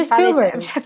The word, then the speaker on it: Spielberg: Steven Spielberg.